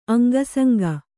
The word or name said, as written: ಅಂಗಸೋಂಕು